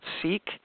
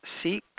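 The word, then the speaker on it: SEAK: There's an organization out there called SEEK, and it's spelled S-E-A-K-S, SEEK, and they have a ton of books on expert witnessing and consulting.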